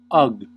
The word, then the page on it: ugh: American English